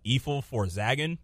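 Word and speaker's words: Efil4zaggin: And even though they only dropped two albums, they dropped Straight Outta Compton, and their second album was Evil for Zaggin. That's how you pronounce it, because backwards it pronounces niggas for life.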